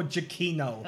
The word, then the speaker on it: Giacchino: Michael Giacchino.